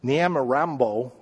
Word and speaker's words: Nyamirambo: The third example I just want to gesture toward is the village of Nyamirambo, I think is how it's pronounced, N-Y-A-M-I-R-A-M-B-O. N-Y-A-M-I-R-A-M-B-O.